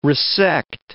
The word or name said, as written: resect